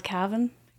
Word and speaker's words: Cavan: It's called Cavan. – Calvin. – Calvin. – I think I said that wrong.